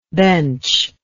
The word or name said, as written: benchless